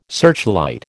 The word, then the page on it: searchlight: American English